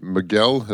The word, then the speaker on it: Miguel: It was with her and Miguel.